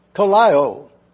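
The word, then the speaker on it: kalao (κολλάω): Kalao. To glue, to unite. The original word is K-O-L-L-A-O. It's a verb. How do we pronounce it? Phonetically, kalao.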